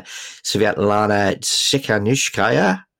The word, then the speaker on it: Svetlana Tsikhanouskaya: But democracy and free speech worldwide are also in crisis, so it could go to a dissident like the Belarusian opposition leader Svetlana Tsikhanouskaya.